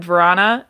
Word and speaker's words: Verana: Exciting news, Jacob Verana, which is apparently how he would like his name pronounced, according to Ken and Mick.